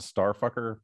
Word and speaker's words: STRFKR: There's a band called Starfucker, but it's spelled STRFKR.